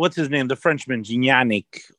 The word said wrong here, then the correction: Gignac: Like cognac, but Gignac.